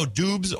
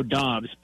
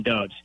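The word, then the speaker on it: Doubs: Uh, how do I pronounce it? Romeo Dubes or Romeo Dubs? Dobbs? How is, how, how, how do we say it? – It's Romeo Dobbs, but he is fine if you call him Dubs.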